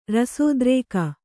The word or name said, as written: ರಸೋದ್ರೇಕ